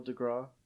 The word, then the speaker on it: deGrasse: And it was Neil deGrasse Tyson, right? Is that how to pronounce it? Yeah, Neil deGrasse.